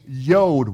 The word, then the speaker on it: yod: The 10th letter of the Hebrew alphabet is called a yod, Y-O-D. It rhymes with mode.